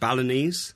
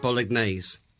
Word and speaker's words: Balinese: I'm not sure how to pronounce that word. – Balinese. – I was told it would involve traditional... – You're saying it Bolognese.